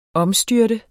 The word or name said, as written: omstyrte